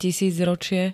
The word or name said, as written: tisícročie